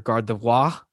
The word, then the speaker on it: Gardevoir: I've been calling it Gardevoir my entire life. Apparently, like the hardcore Pokemon collectors are Gardevoir, like they pronounce it all Frenchy.